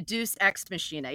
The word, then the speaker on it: Deus Ex Machina: And as far as I pronounce it, the Deus Ex Machina. – The Deus Ex Machina, yeah, because they took a big deuce all over this season, and the Bravo machine said, get the fuck out.